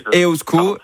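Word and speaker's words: Eusko: Non, mais vous faites bien déjà, Iban, vous dites EOSCO, donc on va déjà le prononcer correctement, ça c'est la moindre...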